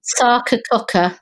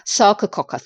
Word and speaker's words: Sarcococca: Sarcococca. – Sarcococca, thank you.